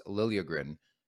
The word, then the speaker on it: Liljegren: By the way, it sounds like that's the way he wants it pronounced, Liljegren.